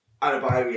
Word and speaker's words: Adebayo: But Abiedu, or whoever number 11 was for Luton. – Adebayo, yeah, sorry.